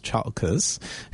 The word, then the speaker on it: Chalkis: This is based on a short story by Christos Chalkis, whose surname I always mispronounce, so forgive me. – You got it right.